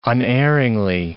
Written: unerringly

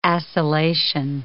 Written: acylation

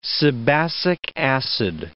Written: sebacic acid

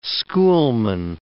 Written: schoolman

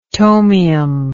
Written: tomium

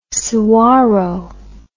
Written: sahuaro

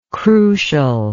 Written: crucially

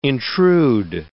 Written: intrude

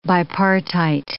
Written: bipartite

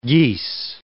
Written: geese

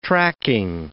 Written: tracking

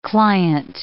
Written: client

